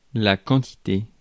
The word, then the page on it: rate: /ɹeɪt/